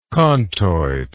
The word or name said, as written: contoid